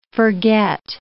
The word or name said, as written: forget